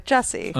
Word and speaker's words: Jussie: Like Jesse, but Jussie.